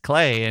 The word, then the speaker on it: Klay: but I think I can handle K-L-A-Y as Klay. And he said, well, actually, it's Klay.